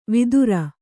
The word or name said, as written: ವಿದೂರ